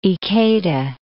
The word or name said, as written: Ikeda